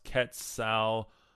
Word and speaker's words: Quetzalcoatl: And Quetzalcoatl is, it's Spanish pronounced Quetzalcoatl. And let's see.